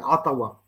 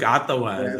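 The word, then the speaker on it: Ottawa: ככה אגב הקנדים מבטאים את זה, עטאווה. – בדיוק, עטאווה.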